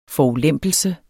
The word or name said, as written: forulempelse